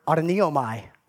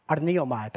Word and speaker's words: arneomai: This word deny that Jesus is using right here is actually the Greek word, try to get the pronunciation right, the Greek word arneomai, arneomai. That's the Greek word, arneomai.